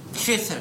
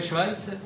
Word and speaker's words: Schweitzer: No to se vyslovuje Švajce.